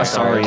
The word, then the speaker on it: Astarion: he's standing like he is Asterion. What's Asterion? Is that his name? How do you say Astarion.